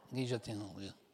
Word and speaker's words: guillotine: Y el que inventó la guillotina, que se llama guillotine, mira que. Guillotine, hay que pronunciarlo en francés, ¿no?